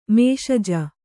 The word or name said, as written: ಮೇಷಜ